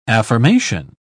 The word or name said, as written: affirmation